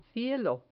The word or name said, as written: cielo